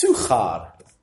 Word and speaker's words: Sychar: But I looked it up in the Greek, and it's actually pronounced Suchar, okay, in the Greek.